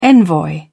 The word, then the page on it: envoy: British English